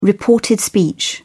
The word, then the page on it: reported speech: British English